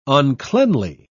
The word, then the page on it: uncleanly: British English